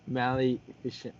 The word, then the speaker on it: Maleficent: So you got Maleficent. Oh, shoot, I can't even pronounce Maleficent.